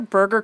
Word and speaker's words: Berger: These are burger cookies. – Like hamburger cookies. – Like hamburger cookies, except not... Because I think it must be named after the people that make them because it's spelled B-E-R-G-E-R.